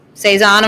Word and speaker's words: Saison: Like you can tell that you've thought like, what is Saison, am I saying it right? – Yeah.